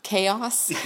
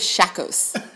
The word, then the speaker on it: chaos: Chaos. – Yeah. – So chaos. – Which is spelled C-H-A-O-S, correct? – I thought she said it was shakos.